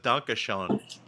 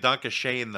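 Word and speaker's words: Danke Schoen: He started out with Donkashon. – Yeah, yeah. – Pronouncing it Donkashane, though, because we're Americans and we pronounce everything wrong.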